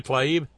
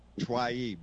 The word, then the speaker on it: Tlaib: How do you say that, Bob? – Rashida Tlaib. – Tlaib, that's it, Tlaib, Rashida Tlaib, the one that said impeach the motherfucker.